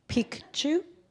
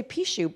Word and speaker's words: Picchu: I'm interested in the Machu Picchu trip, but do you notice that I'm pronouncing that right? Picchu. Picchu. No, because I used to say Pichu, but then I found out that was a man's private part.